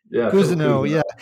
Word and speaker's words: Cousineau: You mentioned there's a wonderful quote from the guy who wrote the foreword to the book, Phil Cousineau. I'm probably saying his name wrong. – Cousineau.